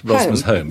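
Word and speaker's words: Rosmersholm: It's the home of Rosmer. That's the way to remember it. Rosmer's home.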